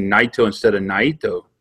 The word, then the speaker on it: Naito: or I said Tetsuya Naito, because that's how I pronounce it, and then the more I listen to the opening ring announcing, I realized it was just Naito.